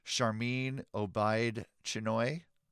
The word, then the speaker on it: Sharmeen Obaid Chinoy: It's co-written by, co-writing the new Lucasfilm movie with Sharmeen Obaid Chinoy.